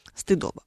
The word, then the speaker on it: стыдоба: Очень часто мы слышим о такой штуке, как стыдоба. Нет ее такой на самом деле. Было бы верным делать здесь ударение стыдоба.